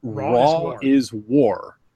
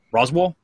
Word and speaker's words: Raw is War: And people would pronounce, it was Raw is War. They'd walk up, Roswell? Roswell? Like New Mexico? – Yeah, I had no clue.